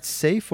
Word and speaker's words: SAFe: Safe or is it SAFE? – It's safe, actually. Okay.